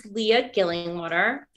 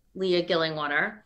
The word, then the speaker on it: Leah Gillingwater: It's Leah Gillingwater. When you spell it, you can't say it with a British accent, but it's still Leah Gillingwater.